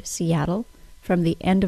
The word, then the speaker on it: Seattle: The final sound of the word Seattle is a syllabic L. A syllabic L is an L sound that has no vowel sound in the syllable with it.